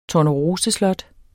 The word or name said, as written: TORNEROSESLOT